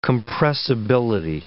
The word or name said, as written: compressibility